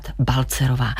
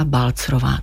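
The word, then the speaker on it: Balzerová: Paní Eliško, myslím, že národ si uvykl říkat Balcerová. Vy ale v úvodu audioknihy Roky říkáte Eliška Balcrová. Takže takhle vás máme vyslovovat.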